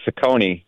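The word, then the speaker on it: Cecconi: Well, first of all, and you pronounced it correctly, by the way, Ciccone.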